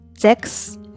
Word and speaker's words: sechs: 在这里边呢，需要大家注意的就是这个数字六的发音，嗯，词尾的 c、 h、 s 合在一起发 x 的音，所以是 sechs, sechs。